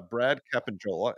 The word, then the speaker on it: Brad Capanjola: Brad Capanjola, and I think I pronounced his name right. – He'll tell you if you didn't.